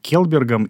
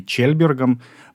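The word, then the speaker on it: Kjellberg: он был как раз э, запатентованным основателем э, компании SAP Оскаром Келбергом или Чельбергом произносится по-шведски Чельберг, а пишется, начинается с Кей, а, в 1904 году.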